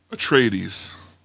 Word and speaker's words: Atreides: Atreides.